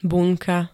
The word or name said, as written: bunka